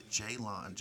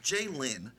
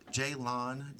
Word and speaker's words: Jaylon: So anyway, you can find me on Instagram and Twitter at Anointed Jaylon, J-A-Y-L-O-N, not Jaylin. I heard that all my life. Jaylon, J-A-Y-L-O-N, pronounced like J in the front lawn in your house.